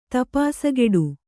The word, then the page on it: ತಪಾಸಗೆಡು: Kannada